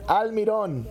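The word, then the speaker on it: Almirón: Almiron.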